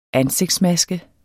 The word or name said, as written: ANSIGTSMASKER